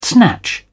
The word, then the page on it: snatch: British English